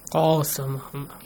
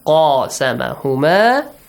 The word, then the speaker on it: قَاسَمَهُمَا: It's a heavy letter, so you gotta pronounce it. Heavily. How do you pronounce Hassan? Qa. Qasamahuma. How? – Qasamahuma. – Yeah. Qasamahuma.